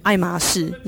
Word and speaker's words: 爱马仕: 他说我根本连那个读音我都不知道怎么读，不，爱马仕，那是爱马仕。